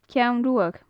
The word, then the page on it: ceann ruadh: gaelic